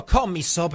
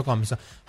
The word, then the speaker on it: Commisso: Manuel Moroni mi comunica che Rocco Commisso, perché non è commisso, lo pronuncia correttamente, quindi finiamo dicendo Commisso.